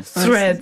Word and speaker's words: Threads: Comment vous le prononcez? – Threads. – Je me posais encore Threads. Cette question ce matin. C'est... Je dis Threads. – C'est Threads. – Si je crois que c'est ça, ouais.